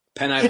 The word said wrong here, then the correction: penne: No, that's not even how that's pronounced. – Pasta? – Penne pasta.